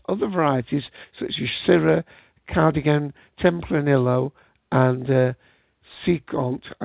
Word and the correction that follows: Cinsault: And I pronounced that one, I'm sorry. That's Cinsault.